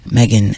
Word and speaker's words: Meggan: Megan, M-E-G-G-A-N, Kressley, K-R-E-S-S-L-E-Y.